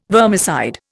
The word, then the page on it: vermicide: Indian